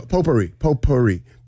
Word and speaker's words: potpourri: P-O-P-O-U-R-R-I. – So close, but incorrect. – Was there a U in there? – There's a silent T in there. – P-O-T. – P-O-T. – What? A T? – P-O-T-P-O-U-R-R-I. – It's not potpourri. – It's silent T.